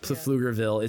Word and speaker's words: Pflugerville: Pflugerville. It's the P is silent for some reason. It's Pflugerville.